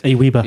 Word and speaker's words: AWeber: I know Aweber or Aweber, I'm not quite sure how you pronounce it. – Aweber.